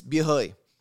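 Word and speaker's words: bia hơi: Oh, and I was talking about this actually earlier with one of the accounts that we were visiting, is bia hoi. Bia hoi, bia means beer. It's literally the Vietnamese pronunciation of the French word beer.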